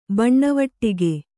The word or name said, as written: ಬಣ್ಣವಟ್ಟಿಗೆ